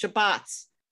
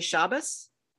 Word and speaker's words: Shabbos: So I thought the English Shabbats gave off that vibe much better than the Hebrew Shabbat. And there's a difference in pronunciation. If you come from a traditional Eastern European background, you would say Shabbos.